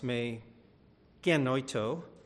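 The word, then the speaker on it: may genoito (μὴ γένοιτο): And so in Greek, it's pronounced, may genoito, as I have highlighted in past messages.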